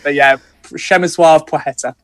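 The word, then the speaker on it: Płacheta: It's Szemysław Plecheta or Płocheta. So Płocheta and Szemysław. It's not easy.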